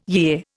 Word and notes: yea: Indian

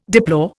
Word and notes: deplore: Indian

